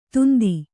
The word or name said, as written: ಧಂಧೆ